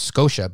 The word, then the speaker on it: Scotia: It's one of those tough words to pronounce, DB. I think if you're Canadian, everybody knows it's Scotia, but I think if you're south of the border, you don't really know how to pronounce that.